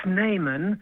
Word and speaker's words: Snyman: Well, it's spelled S-N-Y-M-A-N, and you say it Snayman instead of what it looks like, which looks like Snyman.